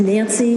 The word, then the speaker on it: Nancy: Chairman Carey, members of the committee, my name is Nancy Leeper. That's spelled N-A-N-C-Y. Last name is L-E-I-F-E-R.